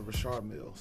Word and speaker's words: Richard Mille: I'm like, yo, if you all rap about a Richard Mille, stop calling it Richard Millie. It's not called that. – Yeah. – It's a Richard Mille.